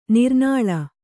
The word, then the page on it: ನೀರ್ನೆಲ: nīrnela